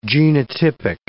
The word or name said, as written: genotypic